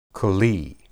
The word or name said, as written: keli